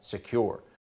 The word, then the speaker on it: Sekur: That's why you need a new cybersecurity company called Secure. It's spelled S-E-K-U-R.